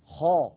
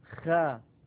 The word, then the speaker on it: خ: خ خ، لاحظوا بأن صوت الخاء يصعد هكذا إلى سقف الحلق. خ، ولا نقول خا خا، إذا قلنا خا فقد تسفل الصوت بالخاء وليس هذا نطقا فصيحا.